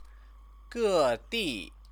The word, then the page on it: gedi: chinese